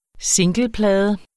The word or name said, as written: singleplade